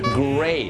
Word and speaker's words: gray: gray ก็คือสีเทานะครับอย่าลืมนะฮะ l กับ r ต้องออกเสียงในภาษาอังกฤษนะครับห้ามข้ามเหมือนเวลาภาษาไทยครูคิดไม่ได้ต้อง gray